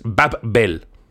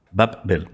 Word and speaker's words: Babbel: O sea, sería, si quieres pronunciarlo como muy estricto, es Babel. Babbel. Babbel.